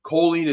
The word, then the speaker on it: choline: And now what we're hearing is we're deficient in choline. And for those of you who are spelling challenged or my pronunciation isn't great, choline is C-H-O-L-I-N-E.